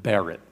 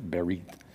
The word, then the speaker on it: berit: So let's go to this slide that's got a definition of the Old Testament word, the Hebrew word berit. Berit, I think is how they would pronounce it.